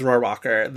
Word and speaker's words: Rohrwacher: I have heard enough people who have worked with her pronounce it as Rohrwacher that like Lazzaro.